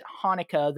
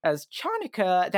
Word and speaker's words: Hanukkah: it's better that they pronounce it Hanukkah than that they think the CH makes the normal English CH sound and pronounce Chanukah. It as Chanukah. If you pronounce it as Chanukah, that's just wrong.